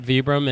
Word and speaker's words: Vibram: People in Italy call it, or Europe call it Vibram, and in the U.S. most people know it as Vibram. – Okay. – So I started Vibram USA back in 2001.